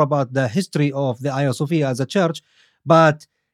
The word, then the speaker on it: Hagia Sophia: The Hagia Sophia, as the Turks would pronounce it.